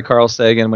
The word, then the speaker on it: Carl Sagan: Yeah, so yeah, you said it right, Carl Sagan, when he wrote that essay around the pale blue dot.